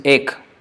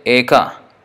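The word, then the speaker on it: Eka (एक): So here, in Sanskrit we say Eka.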